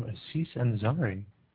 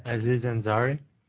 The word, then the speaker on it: Aziz Ansari: and from Aziz Ansari. – You pronounced that wrong, but all right. – Aziz Ansari.